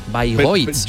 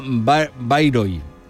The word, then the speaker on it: Bayreuth: Ah, que está en Alemania, mucha gente se piensa que está en el Líbano, que sí, es como Beirut. No, Bayreuth. – Yo lo que tenía entendido era que con acento en la primera, Bayreuth.